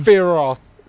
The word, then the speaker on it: Sephiroth: Don't pronounce the P. – That's how you say the name. – Sephiroth.